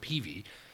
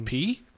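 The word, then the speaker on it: Peay: It's P-E-A-Y, but I believe I've always heard it pronounced Peavy. – Are you really supposed to say Peavy? The Peavy family from Missoula. – So is it Austin P? – P? – There's no V in there.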